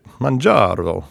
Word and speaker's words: Mounjaro: Mounjaro. Yeah, we were in Florence. – We're in Florence, yeah. For God's sake. You had a bottle of Chianti on your podium when you were speaking. – So Mounjaro is a once-a-week injection approved for people with type 2, essentially.